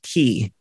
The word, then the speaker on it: Qix: Key. It's an old, and it's spelled QIX.